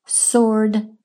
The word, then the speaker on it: sword: Sword rhymes with Lord.